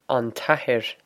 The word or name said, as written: an t-athair